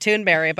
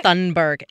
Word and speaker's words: Thunberg: It is actually technically pronounced Thunberg, but everyone says Thunberg.